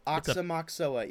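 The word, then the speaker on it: Aoxomoxoa: Oxomoxoa. – Oxomoxoa, yep.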